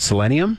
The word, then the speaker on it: selenium: selenium. Mm-hmm. Am I saying that wrong? Selenium? – Yep.